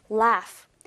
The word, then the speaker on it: laugh: 약간 특이한 게 스펠링이 지금 L-A-U-G-H라고 나와 있지만 G-H의 사운드가 마치 F 사운드처럼 F라고 된다라는 거 다시 한번 발음해 볼게요. laugh, laugh. 맞아요. 여기 나와 있는 어떤 U의 발음도 U에 가깝게 나지 않고 있죠. 약간 특이한 발음이기 때문에 laugh. laugh란 단어 좀 유의 깊게 보도록 할게요.